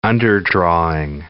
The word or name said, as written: underdrawing